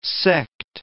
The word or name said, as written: sect